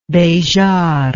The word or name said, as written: bejart